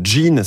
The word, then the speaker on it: Jeen: Vous êtes la cofondatrice de Jean. Ça s'écrit J-E-E-N.